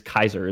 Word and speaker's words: Caesar: It is Kaiser is the correct pronunciation of Tiberius Julius Kaiser.